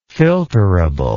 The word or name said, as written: filterableness